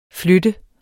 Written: flytte

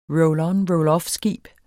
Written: roll-on-roll-off-skib

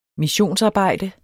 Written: missionsarbejde

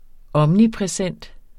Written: omnipræsent

